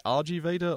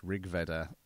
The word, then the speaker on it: Rig Veda: The title itself is pronounced Rig Veda, name of one of the four Vedas.